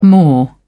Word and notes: maw: British English

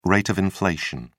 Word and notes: rate of inflation: British English

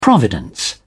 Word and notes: providence: British English

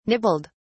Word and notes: nibbled: British English

